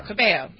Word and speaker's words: Cabello: Cabello.